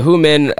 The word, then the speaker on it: Humen: It's spelled H-U-M-E-N. Human. I don't know. – Oh. – Yeah, I know what you mean. – Like who, and then men. Yeah, so he ambushes a Hu Min brigade general and completely decimates his fleet of 35 ships.